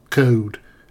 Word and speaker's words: cold: The cold, or code as it's pronounced in Derbyshire, wasn't an issue, however, for 37-year-old Sonia Wilson.